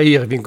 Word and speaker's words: Irvine: Andy Irvine, anzi lui dice che il suo cognome si pronuncia Irving, così mi ha detto l'ultima volta che l'ho incontrato, e quel piper meraviglioso che era Davis Pillen, il disco si intitolava East Wind, se ricordo bene.